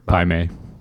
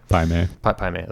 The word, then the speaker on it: Pai Mei: I like the relationship between her and, how you pronounce his name, Mai Pei? – Pai Mei. – Yeah. – Pai Mei.